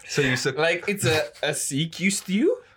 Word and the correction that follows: sequester: Firstly, it's pronounced sequester.